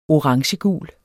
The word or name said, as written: orangegul